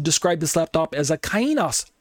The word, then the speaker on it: kainos: By comparison, the Greek word kainos, some people say kainos or something to that effect, kainos, but kainos is how I pronounce it.